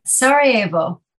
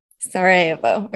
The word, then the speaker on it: Sarajevo: Sarajevo. – Sarajevo.